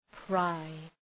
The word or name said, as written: pry